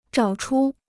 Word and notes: 找出: zhǎo chū

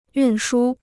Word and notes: 运输: yùn shū

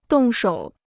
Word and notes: 动手: dòng shǒu

